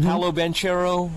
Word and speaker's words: Banchero: How do we say it? Banchero? – Mm-hmm. – Paolo Banchero yes.